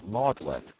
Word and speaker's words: Magdalene: In England, the word is pronounced maudlin, whence maudlin, adjective, unpleasantly sentimental.